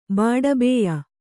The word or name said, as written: ಬಾಡಬೇಯ